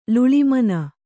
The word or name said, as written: ಲುಳಿಮನ